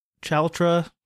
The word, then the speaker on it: Chalotra: I want to give some credit to Anya Chalrota. Chalotra, sure. I'm sorry I butchered your name if you ever listened to the show.